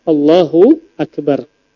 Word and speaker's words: Allahu Akbar: Kemudian juga tidak sah kalau seandainya A-nya dipanjangkan. Allahu Akbar. Makna A apa? Apakah? Nah, maknanya istifham pertanyaan. Allahu Akbar. Apakah Allah Maha Besar? Maka ini merubah arti. Maka sholatnya tidak sah. Maka perhatikan dalam hal pengucapan Allahu Akbar, jangan sampai salah.